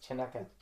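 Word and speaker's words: Chanukah: How do you pronounce that? – It's pronounced Chinooka.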